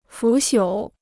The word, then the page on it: 腐朽: fǔ xiǔ